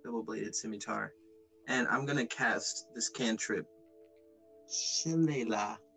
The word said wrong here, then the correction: Shillelagh: um, for, for the record, it's pronounced Shillelagh, but That looks nothing like what it's pronounced.